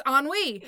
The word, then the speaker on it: ennui: So I look it up and it has the pronunciation and I'm like, holy fuck, that's ennui.